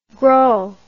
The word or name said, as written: grau